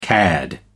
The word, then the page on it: cad: American English